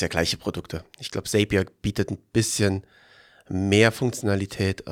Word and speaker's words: Zapier: Du meinst Zapier. Genau. Zapier.